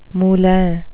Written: moulin

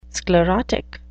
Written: sclerotic

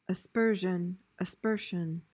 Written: aspersion

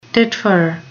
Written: titfer

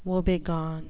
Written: woebegone

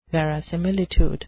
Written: verisimilitude

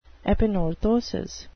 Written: epanorthosis

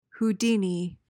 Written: houdini

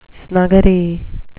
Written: snuggery

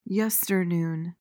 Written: yesternoon

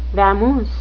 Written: vamoose

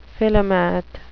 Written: philomath

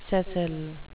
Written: sessile

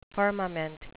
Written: firmament

